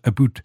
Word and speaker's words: about: Ask those American tourists to say out and about, they will quickly be identified as non-Canadian. We pronounce it a boot.